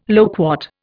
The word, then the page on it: loquat: Indian